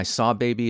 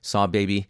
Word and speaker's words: Sahbabii: Barnacles by Saw Baby, and that's spelled S-A-H-B-A-B-I-I, Saw Baby.